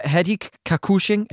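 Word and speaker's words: Hadi Ka-Koush: Det er Hadik Kakushing. Er det ikke sådan, det udtales? – Ja, fra Adam og Nora.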